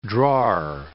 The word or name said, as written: drawer